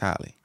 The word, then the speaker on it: Halle: Haley Angel. Hallie, is it Hallie or Haley? I want to say that right. – I think it's Hallie. – I think it's Hallie, right? – Yeah.